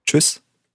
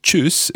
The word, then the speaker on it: Tschüss: And if you've been looking at this week's notes, you'll notice that tschüss is spelt T-S-C-H-U, and there's two dots above the U, and then S-S. The first combination of four letters looks quite daunting, but if we listen carefully to how Thomas pronounces it, we'll see that it's just a strong ch sound.